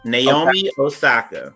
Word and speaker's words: Osaka: Naomi, let me see, make sure I'm saying it right. Osaka? – Yes. Naomi Osaka.